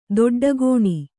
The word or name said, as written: ದೊಡ್ಡಗೋಣಿ